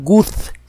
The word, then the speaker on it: Guth: El autor es Alan Guth, se escribe G-U-T-H, y hay un libro de divulgación incluso en nuestro idioma que se llama El universo inflacionario y el autor es Alan Guth.